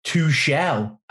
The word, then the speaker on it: Tuchel: I'm gonna say his name is Thomas Tuchel, but Alex Baker and James last week told me it was Thomas Tuchel.